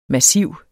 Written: massiv

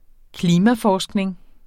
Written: klimaforskning

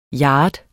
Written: yard

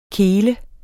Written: kel

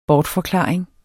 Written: bortforklaring

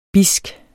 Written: bidsk

